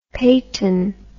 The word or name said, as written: Payton